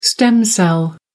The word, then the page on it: stem cell: American English